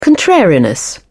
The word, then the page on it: contrariness: British English